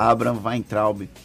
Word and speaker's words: Weintraub: Abraham Weintraub, falei certo dessa Abraham vez? Weintraub. – Abraham Weintraub.